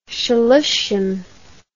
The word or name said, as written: shloshim